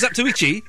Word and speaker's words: Zatoichi: Or go for that swordman that I can't even pronounce it, the Tachi. – That was Zatoichi.